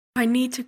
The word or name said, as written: i need to